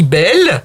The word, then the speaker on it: Bayle: Et d'ailleurs, là aussi, peut-être on va régler le problème de la prononciation de son nom, puisque un Français qui n'est pas du Midi dit Belle. – Voilà. – A-Y, ça fait E. Et puis, vous dites Pierre Bayle, parce que c'est un homme du Midi.